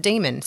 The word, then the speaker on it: daemon: it says the word daemon is to be pronounced demon.